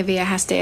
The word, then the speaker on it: viajaste: Ahora que viajaste a Estados Unidos, podemos ayudarte. – Your Spanish is impressive. – Turns out immersion works. – You did mispronounce viajaste. – Viajaste.